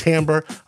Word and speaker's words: Timbre: T-I-M-B-R-E, pronounced Tambre.